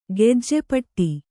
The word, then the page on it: ಗೆಜ್ಜೆಪಟ್ಟಿ: gejje paṭṭi